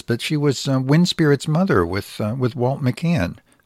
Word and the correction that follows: McEwen: McEwen is how he pronounced it, McEwen.